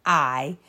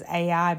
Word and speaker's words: Ai: And there's a couple times I think we refer to it as AI because that's how it's spelled. So as we have this conversation, y'all just forgive us. We're talking about the city of Ai.